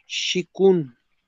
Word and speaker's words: Qigong: Busca en internet, se escribe con Q, Shikun. Así escribe Qigong, así se escribe. El Qigong o el Shikun se llama o significa trabajo fuerte.